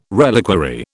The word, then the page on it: reliquary: British English